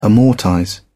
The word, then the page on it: amortize: British English